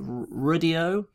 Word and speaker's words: Rdio: And the funny thing with that is that when Apple Music launched, how do you pronounce it? Rudio. It's just spelled RDIO.